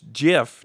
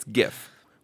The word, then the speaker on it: GIF: So just because this asshole says that it's GIF, giraffics interchange format, it doesn't make it right. It's GIF with a hard G. And if you say GIF in front of us, then we will berate you.